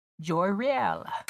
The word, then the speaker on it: Joyriel: Tonight, we are joined by Jasmonae, Joyriel.